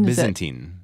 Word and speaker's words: Byzantine: Byzantine. – Yeah. I mean, I'm pronouncing it wrong. – No, Byzantine is correct.